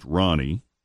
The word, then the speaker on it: Rani: Spelled R-A-N-I, but it's pronounced Ronnie, after Danny's late father, Ron Fujikawa.